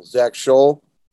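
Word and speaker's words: Zac Shull: My name is spelled ZACSHULL, Zach Scholl.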